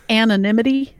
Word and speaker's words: anonymity: I don't know if I pronounced that correctly. – Anonymity? – Anonymity.